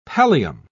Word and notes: pallium: British English

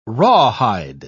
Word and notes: rawhide: British English

